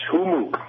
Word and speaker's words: Hummux: H-U-M-M-U-X. Humook.